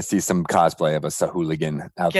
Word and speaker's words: Sahuagin: It's got the face of a Sahuagin. That's how you pronounce it.